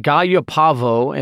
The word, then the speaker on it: gallopavo: So Meleagris or Meleagris gallopavo, and I'm pronouncing it with the double L being the AA there. – Okay. – You can pronounce it gallopavo if you like to pronounce it quesadilla. That's fine. I go with the yuh sound.